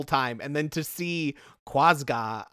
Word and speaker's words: Quazgaa: It is spelled Q-U-A-Z-G-A-A. – Quasga. – Quasga.